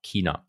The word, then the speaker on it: China: Ähm, China, genau, richtig mhm. ausgesprochen, mit K, China.